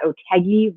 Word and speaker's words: Otheguy: I am Emma Otheguy. If you're trying to remember later, how do you pronounce that woman's name? You can remember that Otheguy rhymes with Peggy.